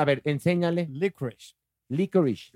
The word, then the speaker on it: licorice: Clararirá que sí, ¿Cómo se pronuncia? – Licorice Muy bien. – Ay, pobrecito, pensaba pero, pero te faltó el shh. Licorice eso, muy bien.